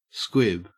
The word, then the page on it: squib: Australian English